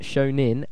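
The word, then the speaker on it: shōnen: Shonen is pronounced Shonin, as in ninny.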